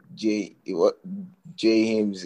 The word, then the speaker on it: Jay Hymnz: Funny enough, the problem is that when I coined that name Jay Hymnz, it wasn't supposed to be pronounced that way, so that it rhymed with my actual name, James.